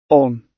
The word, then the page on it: on: British English